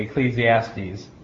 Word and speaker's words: Ecclesiastes: In Latin, is spelled the way we are, in Greek, it's spelled with E-K-K. In Latin, it's spelled E-C-C, but it's pronounced basically the same way, Ecclesiastes.